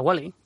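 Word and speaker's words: Whaley: Es la mansión o la casa Wally, no sé si se pronuncia así. – Sí, sí, la mansión, la casa Wally, que actualmente es una casa museo desde el año 1960.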